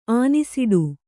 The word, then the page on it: ಆನಿಸಿಡು: ānīsiḍu